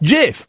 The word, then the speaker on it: GIF: and I say GIF. I know that most of them are thinking, GIF, what an idiot. It's obviously it's GIF. It's not, it's pronounced GIF, but most people don't know that.